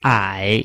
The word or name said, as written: ai3